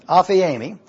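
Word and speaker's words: Aphiemi (ἀφίημι): It is A-P-H-I-E-M-I. It's pronounced Aphiemi. Aphiemi.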